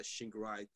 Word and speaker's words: Shingirai: Uh, like I said, it's just my name, uh, Shingirai Chigwere, and I'll spell that because it's a little complicated. Uh, S H I N G I R A I C H I G W E R E, and then also you can reach me on my um on my Instagram at the Shingy.